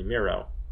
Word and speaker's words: Miro: Or Bernie Miro, rhymes with zero.